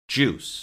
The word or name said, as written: juice